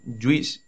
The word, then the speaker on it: JeOS: que se llama Ubuntu Juice. Bueno, se escribe JEOS, pero la gente de Canonical dice que eso se pronuncia Juice.